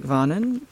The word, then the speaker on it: Vänern: Lake Vänern, is that how you say it? Yeah.